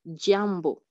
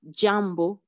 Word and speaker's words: jambo: Par exemple, le mot jambo, jambo. Donc, la façon de prononcer ces consonnes vraiment différent de la façon dont les prononcer en français. Par exemple, ici, on dit jambo comme dans adjectif.